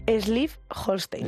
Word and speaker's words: Schleswig-Holstein: Schleswig-Holstein.